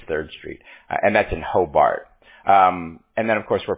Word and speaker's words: Hobart: Hobart, H-O-B. It's H-O-B-A-R-T. Everybody says Hobart. You spell it, you pronounce it the way it sounds, or the way it's spelled, it's Hobart.